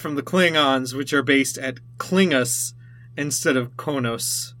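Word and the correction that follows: Qo'noS: Kronos. – What? – It's pronounced Kronos.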